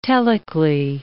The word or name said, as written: telically